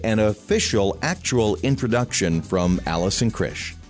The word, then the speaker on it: Krzych: So I am Allison Krisch, pronounced Krisch, but spelled K-R-Z-Y-C-H.